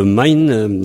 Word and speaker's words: maen: Alors, on avait parlé un peu de mine dans Your Main Here. On écrit M-A-E-N et on prononce main dans la plupart des endroits. Dans certains endroits, on dit mine. Et dans le pays pour l'aide, on dit mine. Alors, au pluriel, c'est main, M-E-I-N.